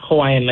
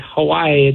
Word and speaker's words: Hawaii: We're also like, technically, we're also pronouncing Hawaii wrong. Like Hawaii, it's Hawaii, you know, because in Hawaiian language, the W's are actually, they sound like V's.